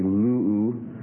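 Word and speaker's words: lu'u (λύου): Number three, second person singular present middle and passive imperative would be lu'u. That's lambda, upsilon, omicron, upsilon. Lambda, upsilon, omicron, upsilon. Stress on the first syllable again.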